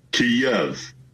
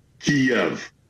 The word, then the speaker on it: Kyiv: Now, the Russian pronunciation is Kyiv. The Ukrainian closest approximation that we can do as non-Slavic speakers is Kyiv. So the Y ends at the first syllable.